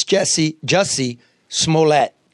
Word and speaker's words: Jussie Smollett: It's Jussie Smollett.